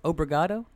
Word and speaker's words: obrigado: It's obrigado is how you say that, wow.